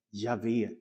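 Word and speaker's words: Jahve: På hebreiska tror man att det ska uttalas Javé, Guds heligaste namn.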